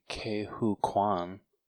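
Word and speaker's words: Ke Huy Quan: I think that would just be Kehu Kwan.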